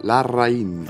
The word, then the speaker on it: Larraín: It's Larraín.